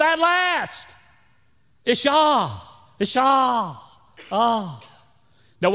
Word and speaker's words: Isha: Now, the Hebrew name for woman is Isha. Isha. I-S-H-A. Let me make sure I spell that right. Okay. Yeah. I-S-H-S-H-A.